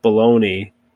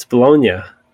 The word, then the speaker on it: Bologna: I said the city of Bologna, and they both kind of gave me this look, is no, it's Bologna.